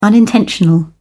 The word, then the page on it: unintentional: British English